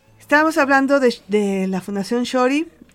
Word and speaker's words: Txori: se llama Shori, se escribe T-X-O-R-I, que eso es una palabra que no es mexicana.